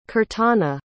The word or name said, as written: Kirtana